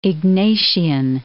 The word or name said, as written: Ignatian